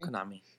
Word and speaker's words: Konami: Konami, is that how you say it? Konami? – Konami. Yeah.